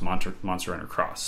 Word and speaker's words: Monster Hunter X: Monster Hunter X, which is apparently pronounced Monster Hunter Cross. So every time you see an X in a Japanese game, by itself, it's cross. Apparently. It's not X.